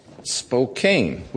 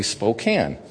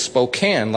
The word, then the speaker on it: Spokane: But it's Spokane, like a tin can, not like the cane of sugar.